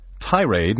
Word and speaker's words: tirade: Tirade may also be pronounced with the accent on the second syllable, tirade.